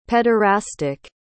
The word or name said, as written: pederastic